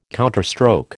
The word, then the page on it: counterstroke: American English